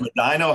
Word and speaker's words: Medina: You introduced it as Medina, Ohio, which is, that's everybody's pronunciation. – Medina. – It's Medina, Ohio.